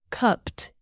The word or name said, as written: cupped